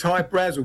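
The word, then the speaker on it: Hy-Brasil: People pronounce it I Brazil, and it's Brazil. Okay. – yeah, but it's High Brazil because that's how the Irish would pronounce it, because it's it's an island that's off the southwest coast of Ireland.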